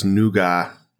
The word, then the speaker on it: nougat: Also, according to the commercial I saw, nougat is pronounced nougat. – What? – What? Where? Who says that? – What? – This is a UK thing, I guess.